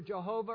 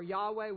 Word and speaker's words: Yahweh: With a reading into Scripture, it's pronounced either Jehovah or Yahweh. We don't know exactly which vowels they would have supplied.